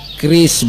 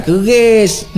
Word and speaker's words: QRIS: Kris, Bu. Kris, kris. Q, R, I, S. Dibacanya kris bukan keris.